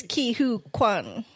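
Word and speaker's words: Ke Huy Quan: His name is Ki Hoo Kwan.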